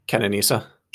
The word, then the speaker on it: Kenenisa: Kelebanisa. How do you say his first name? Bekele? – Kenenisa.